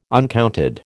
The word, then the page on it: uncounted: American English